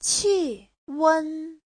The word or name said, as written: qi4wen1